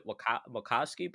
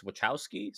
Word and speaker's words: Wachowski: I always called it Wachowski, but apparently it's Wachowski, so...